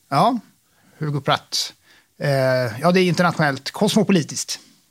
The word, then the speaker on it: Hugo Pratt: Alltså, vi säger Hugo Pratt.